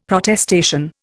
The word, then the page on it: Protestation: Indian